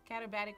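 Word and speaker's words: katabatic: But screaming down the mountains and turning into combative winds. – Meteorologists also. – compressed and warmed by lower altitude. Catabatic winds, sorry. I keep saying it wrong.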